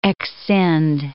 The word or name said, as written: exscind